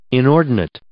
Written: inordinate

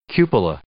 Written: cupola

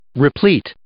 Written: replete

